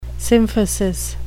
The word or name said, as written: symphysis